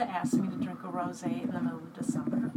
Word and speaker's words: rosé: It's a rosé. It's a rosé. We're drinking a rosé because it is... – Not pronounced rose.